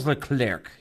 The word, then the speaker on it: Leclerc: Leclerc. – Yeah, Charles Leclerc.